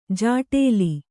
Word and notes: ಜಾಟೇಲಿ: jāṭēli

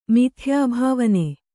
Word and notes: ಮಿಥ್ಯಾಭಾವನೆ: mithyābhāvane

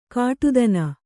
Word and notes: ಘಟ್ಟಿತನ: ghattitana

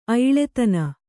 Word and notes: ಐಲುತನ: Kannada

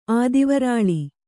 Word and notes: ಆದಿವರಾಳಿ: Kannada

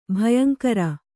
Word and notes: ಭಯಂಕಾರಿ: bhayankāri

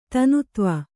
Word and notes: ತೆನೆತೀವು: tene tīvu

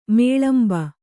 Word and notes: ಮೇಳಂಬ: Kannada

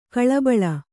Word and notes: ಗಲಿಬಿಲಿ: galibili

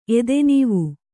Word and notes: ಎದೆನೀವು: Kannada

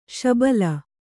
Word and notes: ಶಬಲ: śabala; Kannada

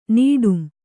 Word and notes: ನೀಡುಂ: nīḍum

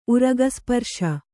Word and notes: ಉರಗಸ್ಪರ್ಶ: uragasparśa

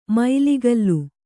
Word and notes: ಮೈಲಿಗಲ್ಲು: mailigallu; Kannada

